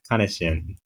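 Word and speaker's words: Kaneshin: Yeah, I think it's Kanishin. It's like the ne, not me, I think.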